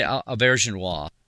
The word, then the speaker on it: Auberjonois: Of course, Rene Auberjonois, or how do you pronounce his last name exactly? I'm sure I always mispronounce it. – I say Auberjonois.